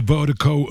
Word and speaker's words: Virdiko: Shouts to Vertigo, appreciate the support, appreciate the love. And Vertigo is spelled V-I-R-D-I-K-O. Pronounced Vertigo. That's V-I-R-D-I-K-O. D-I-K-O, pronounced Vertigo.